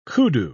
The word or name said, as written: kudu